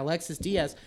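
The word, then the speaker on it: Alexis Díaz: Yeah, Alexis Diaz.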